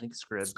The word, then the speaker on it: Scribd: Is that how you say it? Scribed? – Scribd. Scribd.